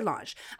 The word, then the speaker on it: L'ange: I'm going to say L'ANGE. And it was founded, it's a relatively new company. This woman named Dahlia L'ANGE founded it in 2017.